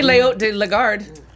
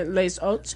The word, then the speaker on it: Les Hautes de Lagarde: It sounds like it would be Le Haut de Lagarde, right? Le Haut? – Well, you'd say the S because the H is silent, so you'd say Les Hautes.